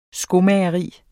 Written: skomageri

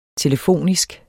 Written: telefonisk

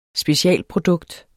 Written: specialprodukt

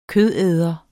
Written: kødæder

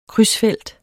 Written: krydsfelt

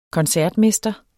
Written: koncertmester

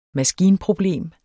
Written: maskinproblem

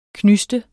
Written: knyste

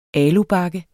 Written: alubakke

